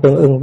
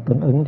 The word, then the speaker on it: tương ứng: Tâm tương ưng với từ, đọc là tương ứng cho nó dễ.